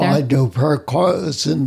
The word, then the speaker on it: paraquat: While I was doing some of my research, I did find that there is a lawsuit for paraquat. Am I saying that correctly? – I believe so.